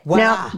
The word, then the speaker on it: Walah: So the next one is Walla, W-A-L-A-H. – Right. – I think I've said that correctly. – Yeah, Walla.